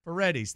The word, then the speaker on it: Paredes: Does Paredes not satisfy your pronunciation, Paredes. – Paredes. Is that better? – Paredes. Thank you.